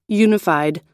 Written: unified

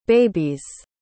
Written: babies

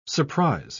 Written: surprise

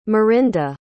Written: Marinda

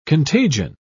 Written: contagion

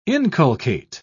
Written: inculcate

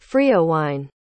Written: freowine

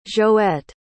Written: joette

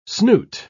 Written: snoot

